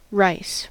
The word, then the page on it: rice: IPA: /ɹaɪs/